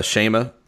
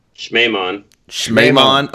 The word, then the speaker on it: Schmemann: That was Alexander Schema. – Schmemann.